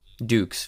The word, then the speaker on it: Dux: Dukes, Frank Dukes. – Well, I remember that we were pronouncing it wrong the whole time, and then we said Dux. His name is Frank Dukes.